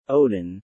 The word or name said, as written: Odin